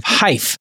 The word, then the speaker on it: Hyfe: He is the co-founder and CEO of Hyph, as in like rhymes with life. It's spelled H-Y-F-E, and it's a smartphone app, and it tracks the sound and frequency of what, Dr. Brew?